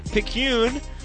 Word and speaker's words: Picayune: Picayune, which is not the way I would have pronounced that. – New Orleans. – But that is how you pronounce it in New Orleans.